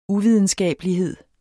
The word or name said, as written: uvidenskabelighed